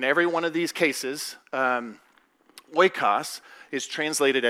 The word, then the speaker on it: oikos: It's this Greek word oikos, and that's how I pronounce it.